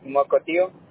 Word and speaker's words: Mukilteo: Mocotío. Se escribe Mosquiteo, pero se pronuncia Mocotío.